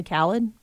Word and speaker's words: Khaled: And then now this latest thing with Drake and DJ Khalid. Did I say that right? I think so. – Probably Khaled. – It might be Khaled. DJ uh, well, Khaled.